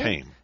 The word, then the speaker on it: Haim: Yeah, Haim? – It's Haim.